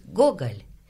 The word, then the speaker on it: Gogol: Entonces decimos Tolstoy y Dostoyevsky, pero en realidad, ¿cómo se pronuncian? – Pues mira, Tolstoy es Tolstoy, uh-huh. Dostoyevsky es Dostoyevsky, Gogol es Gogol. Y en una